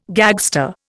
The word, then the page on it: gagster: Indian